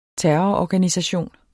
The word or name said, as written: terrororganisation